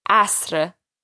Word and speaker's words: asr: This is one of the more trickier words we've learned so far, with the rolled R coming at the end of the word. So watch out for the pronunciation of that word. Asr.